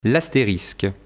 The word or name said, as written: L'astérisque